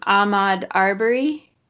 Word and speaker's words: Ahmaud Arbery: Ahmaud Arbery? – Yes, yeah, yeah.